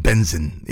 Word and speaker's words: BNXN: B-N-X-N. It's pronounced Benzin.